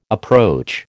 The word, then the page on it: approach: American English